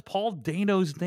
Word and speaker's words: Dano: On last week's episode of the podcast, we incorrectly pronounced Paul Dano's name. It is Paul Dano.